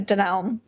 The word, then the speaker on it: Dunelm: D-U-N-H-A-M? – D-U-N-E-L-M. Dunelm. – Dunelm? – Dunelm. – Spell it once more, sorry. – D-U. – D-U. – N. – N. – E-L. M. – Oh, Dunelm. Dunelm.